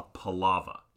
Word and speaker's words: palaver: I, in my head, have been pronouncing it the way that you pronounce it, but I think that could you spell palava for me? – Yeah, palava. It's P-A-L-A-V-E-R. – V-E-R. So I think I would actually say, if I was hearing it for the first time, palaver.